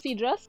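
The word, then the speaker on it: Cedros: Yeah, and I read somewhere as well that about every week, around 1,500 Venezuelans turn up at, is it Cidrus? – Cidrus, yes. – Cidrus, right down there, which is a...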